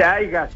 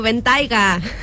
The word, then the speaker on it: Bentayga: No se dice Aiga, se dice Aya, ¿no? No, Bentayga.